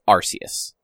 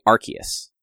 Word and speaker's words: Arceus: Apparently, in Japan, the I don't know whether it's kanji or katakana, like the way that it's written, it is Arceus. – Okay. – Like, it is a soft C sound. – Okay. – But in America, it is apparently Arceus or Arceus.